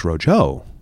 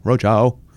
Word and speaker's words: Roadshow: Antiques Roadshow podcast. The emphasis was on the wrong syllable. As I said, podcast. No, show. Roadshow.